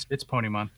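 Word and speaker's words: Ponemon: Am I even pronouncing it right? Is it like Ponymon, maybe? – No, it's Ponymon.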